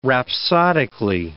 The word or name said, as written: rhapsodically